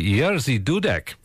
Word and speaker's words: Jerzy Dudek: I would say Jerzy Dudek. You pronounced it so beautifully, Jerzy Dudek.